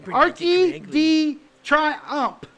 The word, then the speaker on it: Arc de Triomphe: Arc de Triomphe.